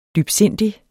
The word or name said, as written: dybsindig